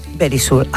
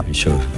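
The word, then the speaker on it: Verisure: Eso aprendido, yo creo que ya lo sabéis, pero Very Sure, ay, que me lo dice, eso es. Bueno. Very Sure.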